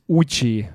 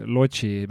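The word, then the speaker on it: Łódź: et seda vist tuleb Uch hääldada või midagi taolist. – jah, N, äh, millel on kriips, läheb Uks jah, poola keeles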